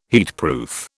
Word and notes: heatproof: British English

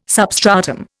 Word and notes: substratum: Indian

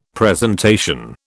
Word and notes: presentation: British English

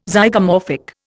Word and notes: Zygomorphic: Indian

